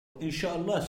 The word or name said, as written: Inshallah